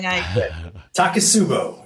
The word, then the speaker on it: Takotsubo: Takotsubo.